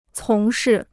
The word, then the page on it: 从事: cóng shì